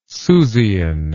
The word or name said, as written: susian